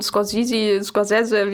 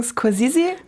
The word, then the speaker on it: Scorsese: Wir sagen Scorsese. – Wir sagen Scorsese, obwohl, glaube ich, die korrekte eher so Scorsese ist. – Obwohl, wir wissen, dass es italienisch anders ausgesprochen wird.